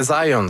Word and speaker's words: Zajonc: Non, c'est Zajonc. – Ouais, okay. J'ai-tu pas dit Zajonc? – Vous avez dit plus Zajonc, mais. – J'ai prononcé un petit peu à l'anglais. J'ai prononcé mon polonais à l'anglais.